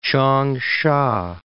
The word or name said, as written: Changsha